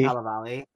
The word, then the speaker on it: Cannavale: James Corden is vocally in it, and it's this hot Italian, Bobby Cannavale. Wow, I can't pronounce his last name either. – Oh, Bobby Cannavale.